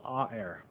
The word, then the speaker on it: aer: This concept of air, it's called A-er, is how it's pronounced. It's actually spelled A-E-R.